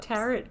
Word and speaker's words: tarot: I called it tarot cards for my life. Tarot. – Rhymes with carrot.